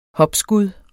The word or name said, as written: hopskud